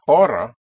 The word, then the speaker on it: hora: Ora. Spelled H-O-R-A means hour, and the H is not pronounced in Spanish.